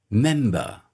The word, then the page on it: member: British English